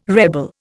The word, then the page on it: rebel: Indian